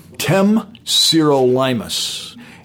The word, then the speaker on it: Temsirolimus: Temsirolimus.